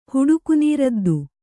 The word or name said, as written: ಹುಡುಕುನೀರದ್ದು